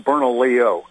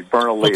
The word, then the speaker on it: Bernalillo: It's pronounced Bernalillo County, Bernalillo.